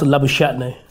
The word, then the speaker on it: Labuschagne: It's pronounced Labuschagne.